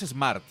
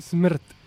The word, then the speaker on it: SMRT: Que no es Smart, no. Son eh, eh, SMRT. – Smart. Smart. Smart. Smart. Pronunciación eslava.